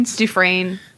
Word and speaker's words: Dufresne: Dufresne, yeah. – Dufresne? It's spelled the D-U-F-R-E-S-N-E. Is that a typo? – Yeah, it's like Dufresne. It's like Dufresne or Dufresne.